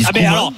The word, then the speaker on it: Koeman: Okay, dans ce cas-là, Koeman, faut qu'on dise Koeman aussi. – On dit Koeman, bien sûr.